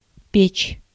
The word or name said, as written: furnace